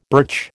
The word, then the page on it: birch: American English